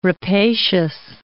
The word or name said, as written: rapacious